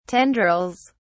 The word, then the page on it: tendrils: British English